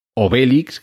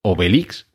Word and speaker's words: Obelix: Entonces la pronunciación debería ser esa, al igual que Obelix, que es como muchos le hemos llamado siempre, en realidad es Obelix, porque también se juega con la palabra obelisco en francés.